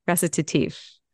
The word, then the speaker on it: Recitatif: And she came in with that question about Toni Morrison's story and recitative. I think that's how you say it because I listened to it on Audible today.